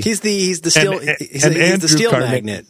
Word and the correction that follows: magnate: Do you pronounce this differently? – Magnate? – You say magnate, like potentate.